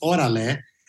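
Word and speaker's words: órale: For all of you that know the LA real estate, and and so it just came to me, and the, and the term is pronounced "orale," uh,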